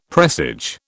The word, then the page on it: presage: British English